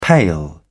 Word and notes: pale: British English

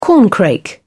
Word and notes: corncrake: British English

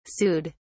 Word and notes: sued: British English

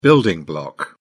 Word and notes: building block: British English

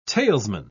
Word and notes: talesman: British English

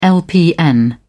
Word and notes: lpn: British English

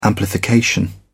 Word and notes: amplification: British English

